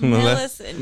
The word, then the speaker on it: Millicent: Millicent? – It's like Maleficent. – Millicent.